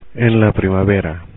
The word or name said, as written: en primavera